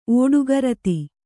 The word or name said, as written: ಓಡುಗರತಿ